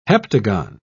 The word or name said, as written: heptagon